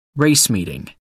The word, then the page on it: race meeting: American English